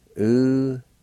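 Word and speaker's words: ulohisdi: U-lo-hi-s-ti.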